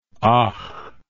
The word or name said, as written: och